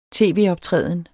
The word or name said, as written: tv-optræden